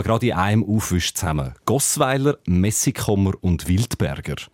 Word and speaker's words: Gossweiler: In der traditionellen Mundart von der Bürgerort der Gossweiler wird der Name sogar als Gossweiler ausgesprochen, noch mit einem langen O im ersten Glied wie bei Gossau.